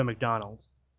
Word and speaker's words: Mcdaldno: I thought we all agreed if we're going to say it right, it's Joe McDonald.